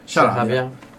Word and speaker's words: Sharabia: Come si pronuncia? Io magari sbaglio. Sharabia? – Sì, Sharabia.